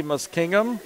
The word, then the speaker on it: Muskingum: And that is coming up on the 12th of October, the head of the Muskingum. – Correct. – Is that what, how you pronounce that? In Marietta, Ohio. – Yep.